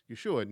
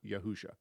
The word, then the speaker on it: Yeshua: And so we know exactly how to pronounce Yeshua. It's Yeshua, not Yahusha.